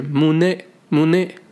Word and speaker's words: 胸: 那第三个呢，咱们学个日语啊，日语的胸口，嗯，发音是 mune mune 啊，这个发音有点像英文当中的 money 那个钱的意思。